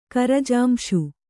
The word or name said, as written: ಕರಜಾಂಶು